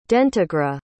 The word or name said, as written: dentagra